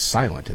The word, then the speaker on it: SLNT: We pronounce it as silent, and that makes sense, doesn't it?